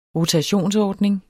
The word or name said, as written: rotationsordning